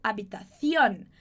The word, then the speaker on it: habitación: Provavelmente, você escutou o cara falando na palavrinha habitación, por exemplo. Você escutou ele falando habitación. Habitación. Como se ele tivesse com a língua presa, assim, sabe?